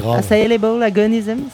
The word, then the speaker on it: A Syllable Agonism: Mais non, mais c'est parce que le A2SAIL, c'est normalement c'est A et après en fait t'as Able, A Syllable. – Ah oui, A Syllable. A Syllable Agonism, ça va.